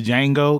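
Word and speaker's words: Django: Do you think that he's a descendant of the Jango? Because you know the D is silent.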